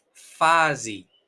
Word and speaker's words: fase: Entonces, vamos ahora para la E. Fasi, fase, fase fasi. Pero hay una E. No, pero nosotros pronunciamos I.